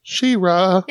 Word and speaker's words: She-Ra: It's pronounced She-Ra.